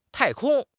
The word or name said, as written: 太空